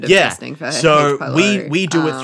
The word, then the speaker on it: pylori: H. pylori. Pylori. Yeah. Thank you.